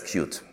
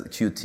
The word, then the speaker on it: Qt: Okay, so everybody inside, you know, Europe says Qt, at least from our side. I know that in the US, there's Qt is externally the more prevalent pronunciation, but I mean, you know, we're saying Qt usually from everybody who works in the company and everybody who works with it closer, so all the contributors.